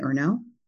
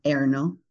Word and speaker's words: Ernaux: I binged all the work of the most recent Nobel Prize winner, whose name I'll pronounce in English and then badly in French, Annie Ernaux. That's Ernaux.